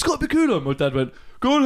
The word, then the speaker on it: Bakula: So, I think that's how you say it. It's Bakula. Scott Bakula.